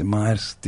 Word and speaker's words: Maersk: Maersk diye yazılıyor ama Maersk diye okunuyor yanılmıyorsam.